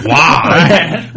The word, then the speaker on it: Roy: Only because of Patrick Roy, which is pronounced Wah, which is the coolest ever.